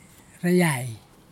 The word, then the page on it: rayai: kwamera